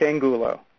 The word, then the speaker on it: Angulo: I hope I pronounced that right. I was supposed to ask him how to pronounce that first, but It's okay. I'll forgive you. It's Angulo. – Angulo. I knew I did. I had the syllable on the wrong, the accent on the wrong syllable or something like that.